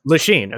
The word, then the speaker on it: Lachine: He constructed the Lachine Canal. Lachine.